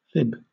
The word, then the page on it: fib: British English